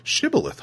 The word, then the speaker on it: Shibboleth: Which, ironically, as soon as he introduced the term Shibboleth, Zelenin goes, "Shibboleth, huh, that's an interesting term. I've never heard of it." And Mastaba just goes, "Oh, you pronounce that well."